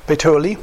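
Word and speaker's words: Puteoli: Die Christen dort hatten von unserer Ankunft und, wie spricht man diese Stadt aus richtig? Peteoli? Wer kann Italienisch oder Lateinisch oder? Peteoli, okay.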